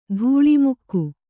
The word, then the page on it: ಧೂಳಿಮುಕ್ಕು: dhūḷi mukku